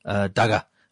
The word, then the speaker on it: daga: Daga. How to make daga. You spell it the same as you pronounce daga.